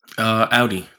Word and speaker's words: Audi: Audi. There was a brochure when you first buy a car from Audi, and it says Audi is pronounced like cloudy.